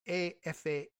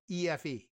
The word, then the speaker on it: EFE: Agencia AFA, if they pronounce anything like Spanish, Agencia EFE reported.